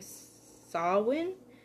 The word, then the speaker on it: Samhain: And November being Samhain is how I learned to pronounce it.